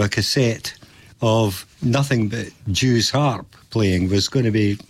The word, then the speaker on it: Jew's harp: Jew's harp, jaw harp, they're pronounced both the same way.